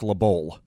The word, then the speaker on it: LeBeau: I think it's the Dick LeBeau, actually. – Is that what it is? – Yeah, if you want to say it right.